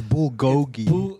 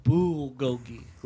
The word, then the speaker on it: bulgogi: Go ahead and say it. – Gugolgi. – It's bulgogi. – It's bulgogi with a B.